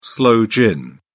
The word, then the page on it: sloe gin: British English